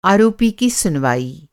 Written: सुनवाई

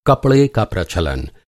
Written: प्रचलन

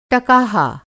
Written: टकाहा

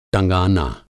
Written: टँगाना

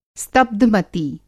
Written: स्तब्ध-मति

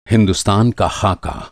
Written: ख़ाका

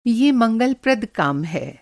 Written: मंगल-प्रद

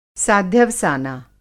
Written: साध्यवसाना

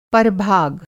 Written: परभाग

